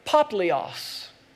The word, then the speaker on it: Publius: How would you pronounce that name? Publius, something like that. I looked it up this weekend. It's actually Poplios. I'm not quite sure why the English spelling got put that way, but it's Poplios.